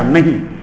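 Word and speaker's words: नः: नहीं, लेकिन न के आगे विसर्ग लग जाए ना जिसको हम नह उच्चारण करते हैं उसका अर्थ होता है हमारा,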